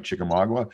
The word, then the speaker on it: Chickamauga: Miles is also one of the most sought-after guides around, and he'll put you on trophy bass on Lake Chickamauga. Am I saying that right? Chickamauga? Right, just north of Chattanooga.